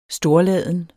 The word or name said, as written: storladen